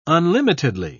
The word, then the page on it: unlimitedly: British English